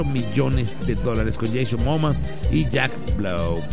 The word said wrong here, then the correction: Black: Bueno, ahí está, Black, perdón.